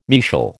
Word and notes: mesial: American English

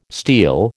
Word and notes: steel: American English

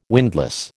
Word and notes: windlass: American English

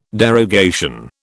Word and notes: derogation: British English

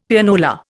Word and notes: Pianola: Indian

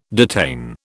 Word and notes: detain: British English

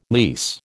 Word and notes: lease: American English

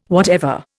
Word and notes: whatever: Indian

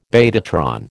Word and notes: betatron: American English

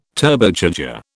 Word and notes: Turbocharger: British English

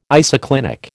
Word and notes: isoclinic: American English

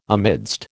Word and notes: amidst: American English

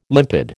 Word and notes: limpid: American English

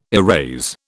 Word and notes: erase: British English

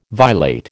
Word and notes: violate: American English

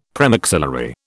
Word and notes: premaxillary: British English

